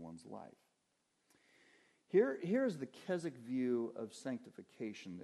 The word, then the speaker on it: Keswick: That word Keswick, it's pronounced Keswick. It's actually a city in England.